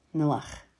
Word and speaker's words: Noach: Noah means rest. Noach. It's with a guttural sound at the end.